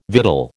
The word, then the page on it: victual: American English